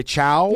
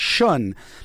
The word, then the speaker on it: Chão: This is commonly mispronounced as Fogo de Chão. – Yeah. – It's the last word is C-H-A-O, but it's Portuguese, and it's actually pronounced more like Shun.